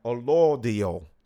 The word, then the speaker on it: allodial: It's A-L-L-O-D-I-A-L, Allodial. Sorry, I'm saying it wrong. Allodial.